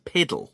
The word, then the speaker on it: puddle: And scholars think that actually the puddle should actually be pronounced piddle.